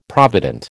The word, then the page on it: provident: American English